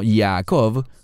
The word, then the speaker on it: Yaakov: El término Yaakov, como se pronuncia en hebreo, viene de la palabra Laakov, que significa perseguir, seguir tras alguien, y seguir tras alguien buscando alcanzar la recompensa.